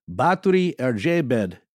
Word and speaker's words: Báthory Erzsébet: Well, in Hungarian, the pronunciation of her name would be something like Báthory Erzsébet de Eched.